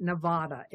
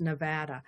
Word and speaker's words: Nevada: Well, one of my dearest friends is from Nevada, and boy, did I learn early on that it is not Nevada, it's Nevada.